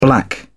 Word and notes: black out: British English

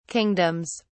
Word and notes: kingdoms: British English

